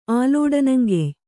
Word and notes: ಆಲೋಡನಂಗೆಯ್: ālōḍanaŋgey

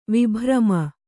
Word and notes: ವಿಭ್ರಮ: vibhrama; kannada

